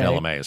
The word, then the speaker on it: Chalamet: Is it Chalamet? – Did it's I, how they say it. Yeah. – I mispronounced it earlier. I beg your pardon. Chalamet. – Yeah, Chalamet is how he says it.